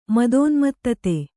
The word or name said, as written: ಮದೋನ್ಮತ್ತತೆ